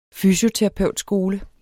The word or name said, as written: fysioterapeutskole